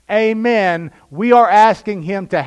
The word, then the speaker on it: Amen: In the original language, that's pronounced Amen. We don't say it that way. We say Amen.